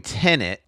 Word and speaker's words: Tenet: Okay, so we're doing Tenet. Let's get the pronunciation correct this Tenet.